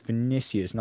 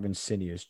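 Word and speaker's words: Vinícius: It's Vinicius, not Vincinius.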